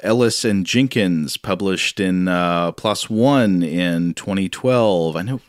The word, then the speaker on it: PLOS: but they say PLOS, rhyming with floss.